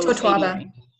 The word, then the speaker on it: Totoaba: The basically the problem is Totoaba. And I don't know if I said that right. Mm-hmm. Totoaba. It's T-O-T-O-A-B-A. – I think it's Totoaba.